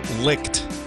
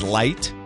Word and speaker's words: Licht: He's the new CEO of CNN, Chris Licht. L-I-C-H-T. Maybe it's light. I've heard it pronounced with a silent C-H.